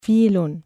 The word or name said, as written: فِيلٌ